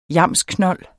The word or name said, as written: yamsknold